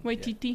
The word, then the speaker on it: Waititi: Waititi.